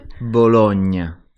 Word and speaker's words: Bologna: And it has this lovely, strange gnu sound in it. Bologna. Can we hear the Italian pronunciation? – Bologna.